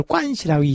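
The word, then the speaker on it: 관시라고 (关系): 음, 우리나라 말로 이제 관계란 뜻인데 이걸 중국 발음으로 하면은 이제 그걸 관시라고 이야기를 하죠.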